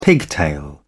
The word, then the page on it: pigtail: British English